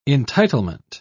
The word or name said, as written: entitlement